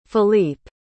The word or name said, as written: Philippe